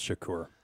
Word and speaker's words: Shakur: It's pronounced Shakur.